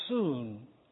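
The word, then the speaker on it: soon (σύν): He uses the little prefix soon. S-U-N is the way it's spelled.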